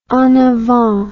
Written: en avant

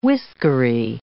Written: whiskery

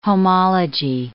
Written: homology

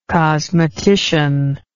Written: cosmetician

